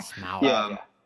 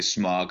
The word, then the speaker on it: Smaug: Smaug. – Smaug. Never say that properly. I would say smog because I'm from Southern California, and that's the thing that used to almost kill me.